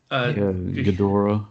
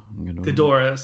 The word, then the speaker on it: Ghidorah: And I'm sure there was some of, I can't pronounce it, Ghidorah. – Ghidorah.